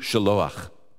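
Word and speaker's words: Shiloach: If I say it right, Shalom? – Shalom, yeah. – Shalom. – In Hebrew, Shaloach.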